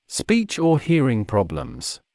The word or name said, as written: speech or hearing problems